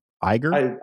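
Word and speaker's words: Uygur: Okay, so first of all, is that how you pronounce Cenk's last name? Iger? – I think. – Is it? I'm not saying you're wrong. Okay, so you're right.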